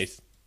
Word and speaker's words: Weisz: Okay. Weiss. The key here is that it's not a V.